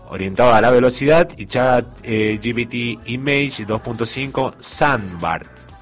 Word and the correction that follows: Sandburst: Sandburst, así se pronuncia, pensando para trabajos que requieran obviamente un control especialmente más detallado de las ediciones.